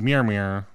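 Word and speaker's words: Mjölnir: I didn't see, but he might have had a tattoo of Mir Mir. – He that's the proper way to say that, by the way.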